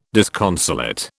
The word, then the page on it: disconsolate: British English